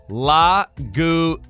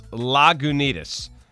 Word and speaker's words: Lagunitas: Ty Cobb on Twitter says, hey, Cap, it's pronounced Lagunitas. Lagunitas.